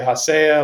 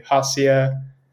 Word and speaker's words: Hacea: So I would say there's no right way to pronounce it or wrong way. I say Hacea. A lot of people say Hacea. It's kind of whatever flows.